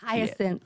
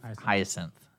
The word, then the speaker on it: hyacinth: So somebody, someone on Facebook's asking, I can't pronounce this flower, so I'm sorry, listener. It's H-Y-A-C-I-N. – Hyacinth. – Hyacinth. Mm-hmm.